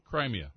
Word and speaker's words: Crimea: Crimea? I don't even know if I'm saying that right. – Crimea.